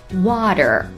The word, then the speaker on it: water: 水这个单词不难，但是要用美式英语的发音把它念准也不是那么容易。 Water， W-A-T-E-R， water。